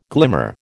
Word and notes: glimmer: American English

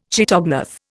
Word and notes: chaetognath: Indian

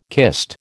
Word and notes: kist: American English

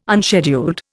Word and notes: unscheduled: Indian

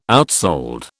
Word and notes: outsold: British English

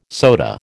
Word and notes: soda: American English

